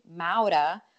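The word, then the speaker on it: Maura: And so in your defense, like, it's hard, you know, we roll the R because it's my name is spelled just like Laura, but with an M. But of course we say Mauda.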